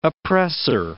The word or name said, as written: oppressor